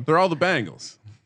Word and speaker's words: Bengals: Isn't it Bengals? There's like three different pronunciations. I don't even know how you just said it. My buddy says he says Bengals like the girl band. – Yeah. – It's Bengals.